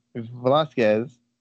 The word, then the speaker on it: Velasquez: When she fought Velasquez, am I saying that right? Yeah.